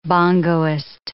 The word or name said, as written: bongoist